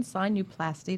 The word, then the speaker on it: sinuplasty: Okay, so we talked about balloon sinuplasty. Did I say that right? – Correct. Excellent.